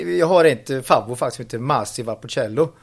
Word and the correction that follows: Valpolicella: Eh, och det är inte så jättedyrt. Valpolicella. Ja, ja. ja var det så det uttalas?